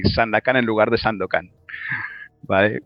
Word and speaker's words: Sandakan: Perdona que haga la broma, no es Sandokan. – No, no, Sandakan, Sandakan. – Pero se escribe, o sea, todo con, con vocales. – Es como Sandakan Sandokan, pero... – Pero todo es en as. – Pero con as todo.